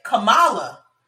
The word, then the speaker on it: Kamala: But yeah, Kamala, damn it, Kamala.